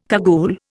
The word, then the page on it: cagoule: Indian